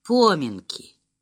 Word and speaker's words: поминки: И поминки». Он так и сказал по-деревенски с ударением на первом слоге.